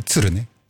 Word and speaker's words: tsuru: ¿Cómo se dice? – Grune, tsurune. – Trune. – Trune. Tsuru.